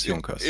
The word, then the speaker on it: Junkers: I don't know if you are doing it on purpose, but correct me if I'm wrong, but I think the correct pronunciation is Junkers. Am I right? – It's Junkers.